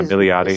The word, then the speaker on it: Milioti: Yeah, Milioti is superb in Black Mirror, and for me, in again in USS Callister, she was a discovery for me.